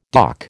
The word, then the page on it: doc: American English